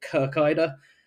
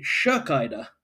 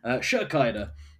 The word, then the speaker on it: Kirkeide: In the women's races, Marin, and I have to apologize to Norwegian listeners because I have been saying Kirkeide. According to the commentary, it's actually Shirkeide. So I promise I'll try and do better on that pronunciation going forward. Shirkeide.